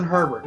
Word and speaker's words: Herbert: And I still can't remember if it's – it's, I think it's Herbert, right? – Yay, learned how to read. – It's Justin Herbert.